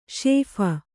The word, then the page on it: ಶೇಫ: kannada